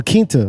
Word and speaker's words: Quinta: Like La Quinta, like the hotel. – Quinta. Quinta. All right, there we go. – She's the fifth Brunson. – The fifth Brunson? – Quinta means fifth.